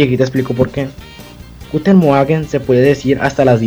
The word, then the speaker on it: Guten Morgen: Guten, Morgen.